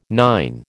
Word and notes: nine: American English

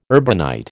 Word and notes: urbanite: American English

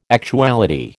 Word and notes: actuality: American English

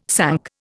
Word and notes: sunk: Indian

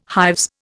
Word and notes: hives: Indian

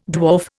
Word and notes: dwarf: Indian

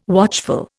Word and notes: watchful: Indian

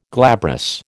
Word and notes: glabrous: American English

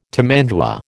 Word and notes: tamandua: American English

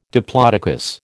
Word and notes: diplodocus: American English

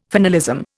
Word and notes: finalism: Indian

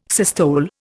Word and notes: systole: Indian

